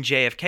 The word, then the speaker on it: JFK: And he pronounces the name literally. During a date with Laura, he says, "We should have seen Jika." Like he pronounces it as it's spelled. Oh, God, he's so stupid. I love it. And when Laura corrects him and says, "You mean JFK," he goes, "I know how it's spelled."